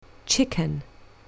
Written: chicken

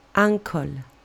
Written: uncle

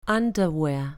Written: underwear

